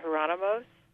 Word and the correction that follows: Jerónimos: Oh, the Jerónimos. Yes. Yeah, that's, yeah.